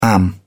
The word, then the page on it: am: British English